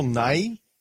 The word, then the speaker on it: Nighy: No emphasis on the Y. Okay. – Nigh. – My apologies to my... – Like my, yeah. But nigh.